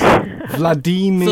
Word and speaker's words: Vladimir: But he also says Vladimir rhymes with redeemer. So it's Vladimir.